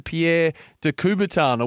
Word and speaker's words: Pierre de Coubertin: Hey, when it comes to the school, Harry, tell me about the Pierre de Coubertin Award. Have I pronounced that correctly? You featured in that, and you won a trip overseas. Is that right? – Yeah. You've done very well there with the pronunciation, far better than what I can do.